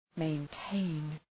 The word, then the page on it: maintain: meın’teın